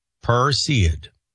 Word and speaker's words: Perseid: Perseid? I believe so. Perseid.